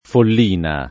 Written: Follina

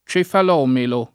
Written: cefalomelo

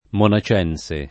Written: monacense

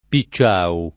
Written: Picciau

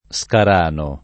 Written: Scarano

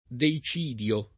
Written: deicidio

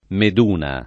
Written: Meduna

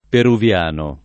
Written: peruviano

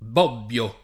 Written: Bobbio

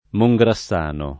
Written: Mongrassano

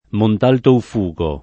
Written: Montalto

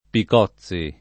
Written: Picozzi

